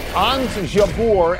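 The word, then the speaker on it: Ons Jabeur: Anz Jabeur and Elena Rybakina will face each other in the Wimbledon women's final. Are you intrigued by that? How is my pronunciation? – Excellent, A, but B, we're a big Rybakina household because my college freshman year roommate was from Kazakhstan.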